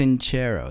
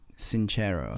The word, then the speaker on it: Sincero: It's actually pronounced Sincero. – Oh, geez. Oh God. – She pronounces it Sincero herself.